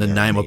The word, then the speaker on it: Nanaimo: Nanaimo. I said that. Did I say that right? Nanaimo? – Nanaimo Bars. – And Nanaimo Bars.